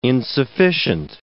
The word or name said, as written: insufficient